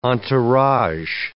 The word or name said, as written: entourage